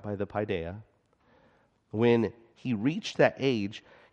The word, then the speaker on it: paideia: When the paideia, I've been saying it wrong, paideia, isn't that like actually an Italian dessert or something? Yeah, paideia, wrong, paideia. That's the Greek and that's the child, okay?